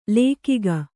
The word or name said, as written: ಲೇಕಿಗ